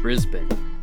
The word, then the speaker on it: Brisbane: Is that how you say it, or is it Brisbane? – Brisbane.